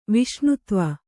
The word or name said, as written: ವಿಷ್ಣುತ್ವ